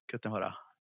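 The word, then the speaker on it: Kutná Hora: Because it's based upon a piece from the Bone Church at, I don't know how this is pronounced, Kutnahora? – Kutnahora.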